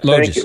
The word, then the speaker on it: Logis: Loges.